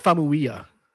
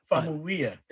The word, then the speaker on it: Famuyiwa: And a director for everybody to watch, and I hope I'm going to say his name right, Rick Famuyiwa. I hope I said that correctly. Please forgive me if I didn't. I think it's Famuyiwa. – Famuyiwa. There we go.